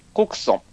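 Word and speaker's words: 谷城: で、ここからですね、事件の始まりということで、物語は、小さな田舎町、コクソン。これ、谷に城と書いて、コクソンと読むらしいです。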